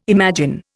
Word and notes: imagine: Indian

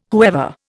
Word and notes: whoever: Indian

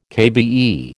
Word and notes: KBE: American English

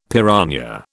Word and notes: piranha: British English

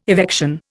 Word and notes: evection: Indian

